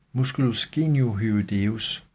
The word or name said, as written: m. geniohyoideus